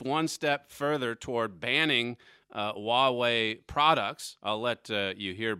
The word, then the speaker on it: Huawei: Huawei, that's right. H-U-A-W-E-I. It doesn't look like it should be pronounced Huawei, but this is like the Chinese Apple company.